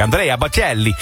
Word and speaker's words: Andrea Bocelli: Andrea Bocelli in het Italiaans. Ik moet het ook altijd zo uitspreken. Andrea Bocelli in het Italiaans.